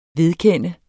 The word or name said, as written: vedkende